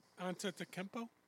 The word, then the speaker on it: Antetokounmpo: Antetokounmpo? – Yeah.